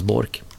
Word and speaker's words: Bork: Leif Bork, uttalas Bork, är tränare för damernas landslag i hockey.